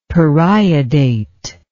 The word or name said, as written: periodate